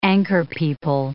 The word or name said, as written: anchorpeople